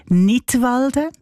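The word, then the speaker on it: Knidwalden: Unter einem Titel mit einem Wortspiel Nidwalden, ausgesprochen wie der Kanton Nidwalden, aber geschrieben mit einem K vorne dran, so wie beim englischen Wort für Lismen, to knit.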